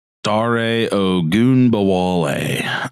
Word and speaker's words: Dare Ogunbowale: Dare Ogunbowale.